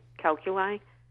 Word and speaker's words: calculi: Bladder is large, three tiny, 1-1.9 millimeters of little grains of sand, calculi, if I'm pronouncing that right.